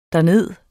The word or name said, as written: derned